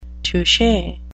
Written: touche

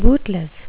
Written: bootless

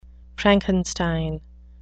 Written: frankenstein

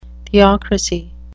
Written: theocrasy

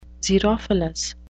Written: xerophilous

